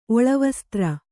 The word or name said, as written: ಒಳವಸ್ತ್ರ